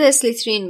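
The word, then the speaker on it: Slytherin: آقای سلطان زاده اسلیترین میگه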